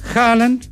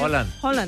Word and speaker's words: Haaland: Porro, Cubarsí, Upamecano, Cucurella, Hernández, Olmo, Bellingham, Messi, Haaland, como dice él que se pronuncia. – Haaland.